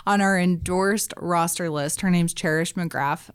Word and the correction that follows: DeGraff: Mm-hmm. DeGraff. I just said that wrong. Cherish DeGraff.